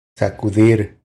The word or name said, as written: Sacudir